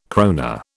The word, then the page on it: krone: British English